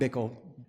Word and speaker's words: Bickle: Mike Bickle. – Bickle? – B-I-C-K-L-E.